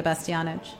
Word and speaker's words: Bastianich: And Lydia Bastianich.